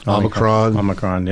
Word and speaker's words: Omicron: So with the new one, Omicron? Am I saying that correctly? – Omicron.